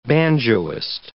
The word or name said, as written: banjoist